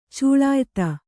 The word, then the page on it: ಚಲಯುತ: Kannada